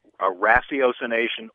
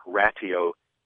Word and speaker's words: ratiocination: It's pronounced a couple of different ways. – Yeah. – Ratiocination or ratiocination. – Cination, yeah. – But it's that beginning of ratio and then cination.